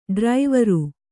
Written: ಡ್ರೈವರು

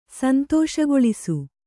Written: ಸಂತೋಷಗೊಳಿಸು

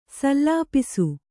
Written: ಸಲ್ಲಪಿಸು

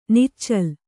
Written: ನಿಚ್ಚಲು

